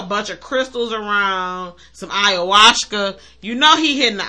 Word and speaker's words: ayahuasca: But I said that right. – Ayahuasca. Yeah, no, you said it right.